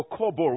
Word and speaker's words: Kobor: K-O-B-O-R. – Oh, Colbor.